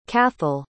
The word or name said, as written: cathal